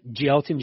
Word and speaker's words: Gelton: Our main event, his name can't be pronounced Gelton, is it? Gelton Jr.? – It's Gelton. – Gelton. It's spelled Gelton, but yeah, Gelton Jr., from Brazil, of course.